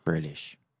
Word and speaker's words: Grealish: Grealish. Ja, Grealish gewoon. Volgens mij zei Willem Wijs net in de live show, die had een naam. – Die zei volgens mij mm, Grealish, uh, Grealish, maar dat is volgens mij niet helemaal correct.